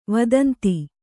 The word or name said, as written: ವದಂತಿ